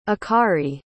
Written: akari

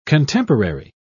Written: contemporary

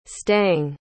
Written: stang

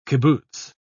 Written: kibbutz

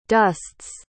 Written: dusts

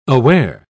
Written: aware

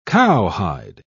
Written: cowhide

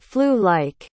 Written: flu-like

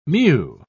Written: mew

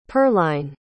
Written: pearline

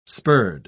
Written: spurred

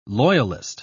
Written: loyalist

